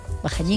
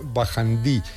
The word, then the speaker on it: Bajañí: También a veces se escribe y se pronuncia Bajandí, pero bueno, creo que aunque se escribía Bajandí, también se seguía pronunciando en Bajañí.